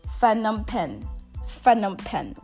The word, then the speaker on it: Phnom Penh: The capital of Cambodia is Phnom Penh, which is spelled Phnom Penh.